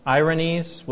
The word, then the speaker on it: Irenaeus: So we're going to be studying, continuing in the Church Fathers, Irenaeus, which that's how I'm told you're supposed to pronounce it, but I seem to pronounce it different at times, so bear with me.